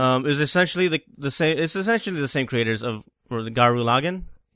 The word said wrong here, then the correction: Gurren Lagann: Gurren Lagann.